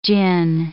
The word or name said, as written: djinn